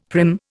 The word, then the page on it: prim: Indian